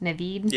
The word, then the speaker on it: Naveen: I'm really excited though because Naveen Andrews, is that how you say his name? Saeed from Lost. Naveen. – Yeah. – Naveen Andrews. – Yeah. That's how I've been saying it.